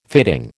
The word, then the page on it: fitting: American English